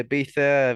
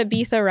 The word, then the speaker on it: Ibiza: I've got holiday booked in July, and then I'll probably go to Ibiza. I've got summer of going crazy. – You said Ibiza right. I hate when people say it wrong. Thank God you got the pronunciation.